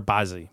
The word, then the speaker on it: Baze: One of the followers was a gentleman named Alex Baze or Bazzi, B-A-Z-E. How would you say that? – B-A-Z, Baze.